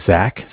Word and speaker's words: Zach: My name is Zach, spelled Z-A-C-H.